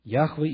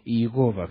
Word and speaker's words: Яхве: Яхве или Иегова, как еще его произносят.